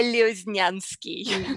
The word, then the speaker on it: Lioznyansky: It's Victoria Lioznyansky.